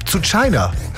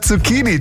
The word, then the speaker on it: Zucchini: Sowas wie zu China. Ja, statt Zucchini, zu China eben.